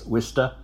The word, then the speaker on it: Worcester: And both pronounced Worcester, Gloucester, and Peabody as Wista, Gloucester, and Pibody.